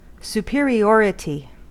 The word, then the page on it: superiority: American English